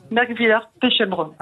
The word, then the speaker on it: Merkwiller-Pechelbrunn: À Merkwiller-Pechelbrunn, je ne sais pas comment on prononce. – Merkwiller-Pechelbrunn. – Ah, j'étais pas loin.